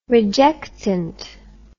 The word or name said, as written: rejectant